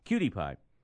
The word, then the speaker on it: PewDiePie: It's spelled D-I-E-P-I-E, but PewDiePie.